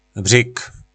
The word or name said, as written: bryg